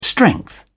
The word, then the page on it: strength: British English